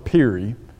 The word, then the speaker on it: Peary: Guy by the name of Robert Peary, I think is how you would say his name. It's spelled P-E-A-R-Y.